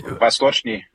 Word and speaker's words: Vostochny: Vostochny Kosmodrom, tror jeg måske, man udtaler det. – Ja, Vostochny.